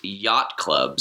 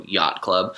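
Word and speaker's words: Yot Club: The next one is Yacht Club. So it's spelled Y-O-T space club, so Yacht Club.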